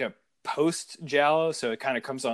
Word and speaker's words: giallo: Giallo? Like, is that how you say it? – Like there's always room for giallo. Yes.